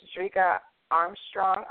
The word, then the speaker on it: Drica Armstrong: But I'm going to go ahead and bring Ms. Drika Armstrong on.